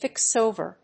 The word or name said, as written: fix over